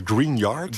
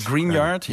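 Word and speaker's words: Gruinard: Het Schotse Greenar Island. – Ja, volgens mij spreek je uit als Greenyard. – Greenyard, Het is een vrij bescheiden eiland.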